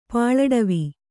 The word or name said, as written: ಪಾಳಡವಿ